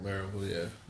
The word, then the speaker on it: Maryville: But I think I'm in the Maryville area. How do you guys say it out here? Maryville? – Maryville, yeah.